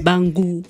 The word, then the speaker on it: Bangou: J'ai bien prononcé ? – Bangou.